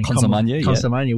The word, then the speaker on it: Consolmagno: Consolmagno, we might also be saying an article or a paper by Dr. Richard D'Souza.